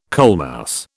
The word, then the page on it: coalmouse: British English